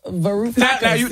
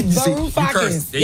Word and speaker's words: Varoufakis: Varoufakis. – Now they on stage. Right. Now they mad because you said their name wrong. – You cursed on the radio 3 times already. – Varoufakis.